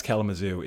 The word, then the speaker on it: Kalamazoo: And yes, Kalamazoo is a real place. It is one of many exotic locales that including and neatly rhyming with Timbuktu.